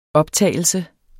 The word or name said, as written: optagelse